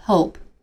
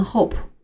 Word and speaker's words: hope: H O P E hope 这个词是希望的意思。发音的时候千万不要发成 hope，尾音要轻一些。